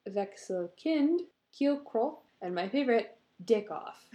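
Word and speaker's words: Dickkopf: D-I-C-K. – Yep. – K-O-P-F. – Yep. That's I that's even, right. Like, I searched all these words on, like, a pronunciation website so I could at least kind of do them justice. Mm-hmm. And then the last one, I was like, that says Dick Off.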